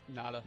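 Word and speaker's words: Ngata: And what they've done with that defensive line, bringing in Haloti, I believe it's the G is silent. – Nada.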